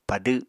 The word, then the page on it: padw: Apatani